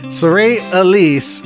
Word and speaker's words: Saray Elise: I was barely learning how to say her name, and I heard another DJ say her name. I was saying it all wrong the whole time. Saray Elise, and this her latest.